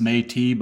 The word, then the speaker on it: Métis: I believe pronounced Métis by one of our Canadian friends.